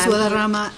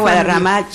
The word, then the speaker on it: Guadarrama: Ms. Guadarrama, you need to make sure that, you know, of all the names that come before me, your last name may be very difficult for other people to pronounce, but it's the easiest for me.